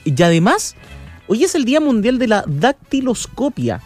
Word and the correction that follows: dactiloscopía: No, dactiloscopía. Ahí está, sí, porque tiene acento en la I, o tilde en la I.